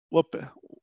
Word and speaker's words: Whopper: Agora, é, tem uma curiosidade, eu não sei no Brasil, mas aqui em Portugal tem um Rebel Whopper, é, como é que. Falei certo? – Falou certinho. – Pronto. – Eu só, só, acho que em inglês é Whopper.